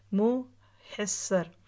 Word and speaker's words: मुहस्सर: बतने मुहस्सर यानी बत महसर, बाज़ लोग इसको मख्सर कहते हैं ना, तो सही प्रोनाउंसिएशन देख लीजिए, मुँह हसर।